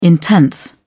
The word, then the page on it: intense: British English